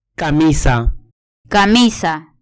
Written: camisa

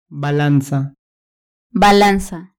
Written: balanza

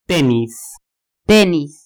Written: tenis

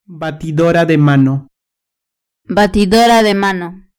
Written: batidora de mano

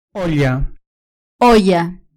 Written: olla

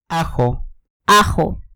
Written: ajo

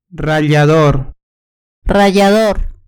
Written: rallador

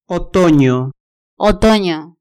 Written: otonio

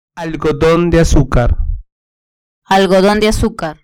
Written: algodon de azucar

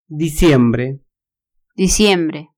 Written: diciembre

